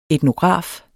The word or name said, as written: etnograf